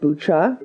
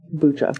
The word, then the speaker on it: Bucha: I'm seeing it is pronounced Bucha. – Bucha. – So I apologize. Yes. So, but, yeah. Bucha.